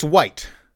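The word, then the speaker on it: wight: It's white, pronounced the same as the color or the absence of color.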